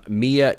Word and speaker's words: Mila: But what they do is they have, the star of the movie is Mila Jovovich. – Mia Jovovich, as she says at the beginning of the film. – Is it really Mia? – Mia Jovovich. So from now on, that is how we pronounce her.